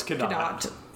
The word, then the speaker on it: Cadott: It looks like it should be pronounced like it's French, Cadott. The two T's means it's a hard T at the end.